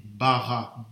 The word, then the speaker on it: bara: En hébreu, ça s'écrit bara, B-A-R-A.